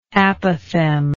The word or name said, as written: apophthegm